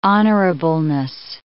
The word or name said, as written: honorableness